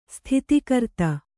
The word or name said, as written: ಸ್ಥಿತಿಕರ್ತ